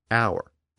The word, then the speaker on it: hour: The loss of the H sound was quite common. We see it in the modern pronunciation of words like hour, H O U R, and honor. The H sound in those words has long since disappeared, but the spelling still reflects the original pronunciation.